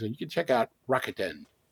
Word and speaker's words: Rakuten: Rakuten, am I saying that right, Joe? – Yes, you are.